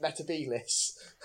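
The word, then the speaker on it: Metebelis: I just wish someone had been on set that actually knew the reference and could have just gently said to Matt, can you pronounce it Metabolis? Like John Pertwee did.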